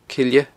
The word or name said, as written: Cille